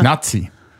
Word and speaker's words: Nati: Nazi. – Mhm. – Ja. N-A-T-I, nicht Z, sondern T. – Ah, okay. – Ja. Man schreibt Nationalmannschaft ja auch nicht mit Z.